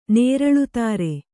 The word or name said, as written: ನೇರಳುತಾರೆ